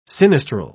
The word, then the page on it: sinistral: British English